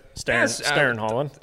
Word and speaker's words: Stjernen Hallen: So it'll be good to get back here at Stjernen Hallen, and I say that right? Stjernen Hallen. – Yeah, I would give you an accepted on that one.